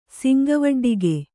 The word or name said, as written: ಸಿಂಗವಡ್ಡಿಗೆ